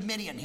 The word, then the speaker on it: Midian: However, this, because this word, Cushan, is parallel here in Habakkuk 3:7 with the proper noun Midian, yeah, it's pronounced Midian.